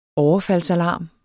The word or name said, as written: overfaldsalarm